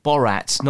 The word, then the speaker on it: Borat: Borat, not Barat.